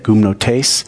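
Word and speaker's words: gumnotes (γυμνότης): Nakedness is gumnotes, is how you say that, gumnotes.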